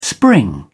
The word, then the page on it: spring up: British English